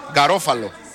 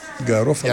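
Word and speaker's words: Garofalo: Και με το νούμερο 2 θα μπει στο παιχνίδι και ο Γκαροφάλο. Γκαρόφαλο. – Γκαρόφαλο. – Για να είμαστε σωστοί.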